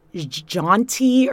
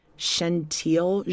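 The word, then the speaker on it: Chentile: It's spelled C-H-E-N-T-I-L-E. I thought they were saying Jaunty or Shanti, but it's Shentile, Gentile.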